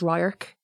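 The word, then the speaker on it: radharc: I think my number one is Ryark, which means view. I've always loved it. I love saying it. I love how it's spelled. I love how it's not spelled the way it's said.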